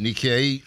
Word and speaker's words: Nikkei: They just opened their second location in Rosslyn, offering traditional Peruvian and Latin dishes with a special take on modern Nikkei.